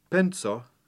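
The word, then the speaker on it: penco: Penso, a penny, is spelt with the letter C after the N, which means that it's pronounced with a TS sound, penso. Try and keep this distinct from the simple N plus S sequence in the word penso, a thought, which is spelt with an S. Penso, penny, versus penso, thought.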